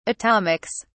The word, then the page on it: atomics: British English